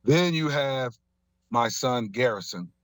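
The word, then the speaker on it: Garyson: So there's Gary's son, but it's pronounced Garrison. If you say it fast, Garrison. It's Garrison.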